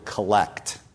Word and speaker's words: collect: Collect spelled the same way you spell the word collect, C-O-L-L-E-C-T. But it's pronounced collect. It means a short prayer.